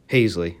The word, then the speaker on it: Haseley: Hesley went 0-3. Haseley. I would say Adam Hesley. It's Adam Haseley.